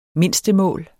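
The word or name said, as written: MINDSTEMÅLS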